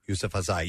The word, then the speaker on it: Yousafzai: Yousafzai. – One gesture. – Yousafzai.